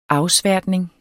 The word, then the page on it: afsværtning: ˈɑwˌsvæɐ̯dneŋ; -ˌsvæɐ̯ˀdneŋ